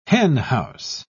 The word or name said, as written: henhouse